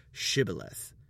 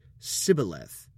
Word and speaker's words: Shibboleth: While trying to sneak past a checkpoint disguised as other soldiers of Gilead, the Ephraimites are stopped and simply asked to pronounce the word Shibboleth. The Ephraimites say Sibboleth, mispronouncing the word by Gilead standards,